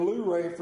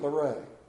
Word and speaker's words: Luray: you don't pronounce it Luray like we up the river would do.